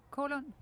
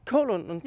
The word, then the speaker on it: Kålund: Hvordan udtaler man dit efternavn? Halund? Kålund. Kålund. – Ja. Det var med et H. Mm. Det var med et K. Ja, K. Kålund. Ja.